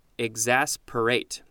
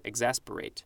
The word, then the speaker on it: exasperate: Circa 1731, all we have are a couple synonyms, irritate and exacerbate, E-X-A-S-P-E-R-A-T. I think I pronounced it wrong. Exasperate. There we go. Exasperate.